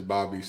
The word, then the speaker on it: Bobi: I mean, Steve For- Steve Forbes pronounced it as Bobby, so I, okay, let's go Bobby.